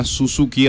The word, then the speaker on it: Suzuki: S-U-Z-U-K-I. – Dice Suzuki. – Como dirían los españoles, Suzuki. – Suzuki. – Suzuki. – Suzuki. – Porque para ellos la Z suena así.